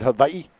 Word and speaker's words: Hawaii: We pronounce it Hawaii. – Hawaii? – Yeah.